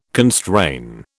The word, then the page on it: constrain: British English